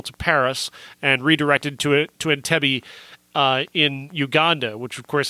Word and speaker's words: Entebbe: Entebbe. – Entebbe.